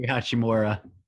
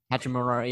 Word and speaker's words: Hachimura: Rui Hachimura. – Hachimura, yeah.